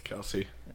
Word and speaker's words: Kelce: Travis what? – Kels, my bad. – Thank you. – It's Kelce.